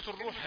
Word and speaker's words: الروح: كثيرون يخطئون في نطق كلمة الروح، فبدل أن يقول الروح يقول الروح الروح، جعلها ضمة يعني مش رائبة إلى الفتح الروح.